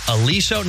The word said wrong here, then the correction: Alesso: It's actually pronounced Alesso, and he's just the guy.